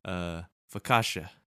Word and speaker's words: focaccia: One bread, breadstick, garlic bread, and we were going to use dinner roll until Rohan reminded us of Italian bread, focaccia.